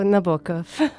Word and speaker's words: Nabokov: although you pronounced his name differently. – Oh, Nabokov.